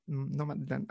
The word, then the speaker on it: Nomadland: no Nomadland. Ay, lo dije mal, parece. Eso. – No, está bien, está bien.